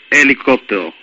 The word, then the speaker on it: helicóptero: No, es helicóptero, con H. – helicóptero. – Sí. – H-E-L-I-C-O-P-T-E-R-O. – La H es muda, aunque, o sea, no se pronuncia.